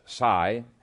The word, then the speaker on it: psi: It would include ESP, PSI, which actually is pronounced psi, uh, all forms of supernatural psychic experimentation.